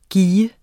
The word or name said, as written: gis